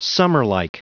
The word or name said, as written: summerlike